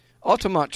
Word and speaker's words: Altamont: Altamont.